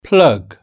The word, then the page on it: plug: British English